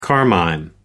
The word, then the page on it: carmine: American English